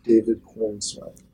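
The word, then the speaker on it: Corenswet: David Cornsweet.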